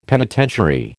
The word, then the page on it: penitentiary: American English